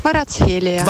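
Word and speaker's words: Kvaratskhelia: Beh, Varaschelia l'ho detto bene, dai. No. – Varazzelia. – Varazzelia, eh, troppo difficile, dai.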